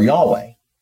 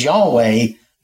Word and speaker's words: Yahweh: When we say this is Yahweh, that is what most scholars seem to believe the pronunciation is.